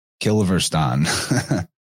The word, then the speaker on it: Kilverstone: Kilverstone, is that how you pronounce that? – Yeah, it's the Kilverstone.